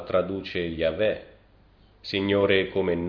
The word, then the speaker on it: Yahvé: L'opinione pubblica generale fu che il nome dovesse essere pronunciato Yahvé, ma ad un certo punto si presentò la pronuncia di Jehovah e questa divenne sempre più popolare. Ma Yahvé è probabilmente la pronuncia corretta, sebbene non lo sappiamo per certo.